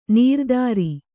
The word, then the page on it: ನೀರ್ದಾರಿ: nīrdāri